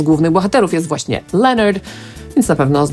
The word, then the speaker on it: Lynyrd: No i właśnie tę pierwszą część wymawiamy tak samo, jak imię Leonard po angielsku, czyli Leonard.